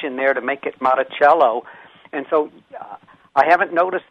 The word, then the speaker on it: Monticello: But every one of them pronounces it different than he pronounced the name of his place. It's called Monticello. Every place else seemed to call him Monticello.